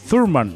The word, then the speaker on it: Thurman: Yo lo estaba pronunciando Turman, pero no, me estaba diciendo el caballo que se pronuncia Thurman. Oh, como con Z, sí, sí, más ah, o menos. Con Z, como Thurman. Con el eh, acento bostoniano, ¿no?